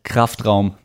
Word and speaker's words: Kraftraum: It's called Kraftraum Podcast. It's just a German word for weight room.